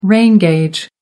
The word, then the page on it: rain gauge: American English